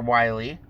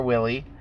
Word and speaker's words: Wily: But his rival, Dr. Wily, or some people call him Dr. Willy, It's true, actually. Some people mispronounce the name, but as I've always known it, it's been Dr. Wily.